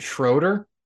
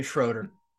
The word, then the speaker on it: Schroeder: Is it Schroeder? – Yeah. – Oh, okay. Schroeder.